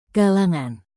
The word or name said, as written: kalangan